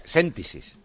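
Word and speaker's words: Séntisis: En la pandemia conocimos una empresa que se llama Sentisis, ¿es Sentisis? ¿Lo he dicho bien? Séntesis. – Sí, o sea, me he fallado en el acento.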